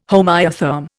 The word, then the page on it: homoiotherm: Indian